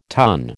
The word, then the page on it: tonne: American English